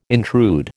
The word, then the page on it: intrude: American English